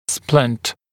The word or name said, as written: splint